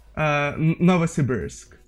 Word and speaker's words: Novosibirsk: it's actually real close to Russian pronunciation. Like in Russian pronunciation, it's Novosibirsk, and in English, Novosibirsk.